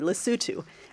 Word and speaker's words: Lesotho: And he mentioned one project in the tiny landlocked African country of Lesotho, which, if memory serves me, he said it correctly, Lesotho.